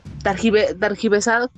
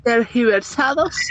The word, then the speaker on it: tergiversados: targiversados, ¿cómo se dice? – Tergi. tergiversados.